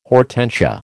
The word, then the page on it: hortensia: American English